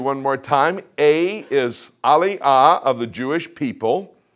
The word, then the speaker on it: Aliyah: That is a Hebrew word. Say that word. Aliyah. Aliyah. The next page will tell you how to spell it. A-L-I-Y-A-H.